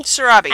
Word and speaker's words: Sarabi: Am I saying that right, Sarabi? – Mm-hmm. Sarabi.